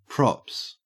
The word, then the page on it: props: Australian English